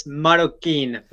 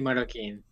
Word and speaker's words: Marroquín: It's Marroquín for the last name. Matthew Marroquín.